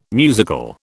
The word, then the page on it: musical: British English